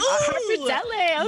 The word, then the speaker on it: Pappardelle: Pappardelle.